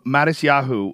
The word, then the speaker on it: Matisyahu: Um, Matisyahu, uh, if you went on stage dressed up like him, is that anti-Semitic?